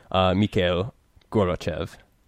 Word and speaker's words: Mikhail Gorbachev: Or some very annoying charts on pronunciation trying to tell me how to correctly pronounce Mikhail Gorbachev. Is that good enough for you? – Mikhail Gorbachev.